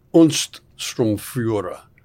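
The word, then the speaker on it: Untersturmführer: So Reichsführer Himmler himself offers von Braun the rank of Unststromführer, which is like a second lieutenant. So a low level. – Daniel, did he get that right? Is that the right way to pronounce that? <That's it.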